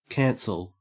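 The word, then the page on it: cancel: au Australian English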